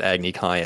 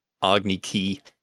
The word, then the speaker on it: Agni Kai: Also, hated how they also mispronounced Agni Kai as Agni Ki.